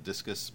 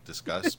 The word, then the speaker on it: Disqus: So you can also pronounce it Discus, but they want to say Discuss because it's a comment and thready and discussy and whatever.